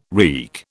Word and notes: reek: British English

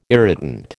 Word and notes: irritant: American English

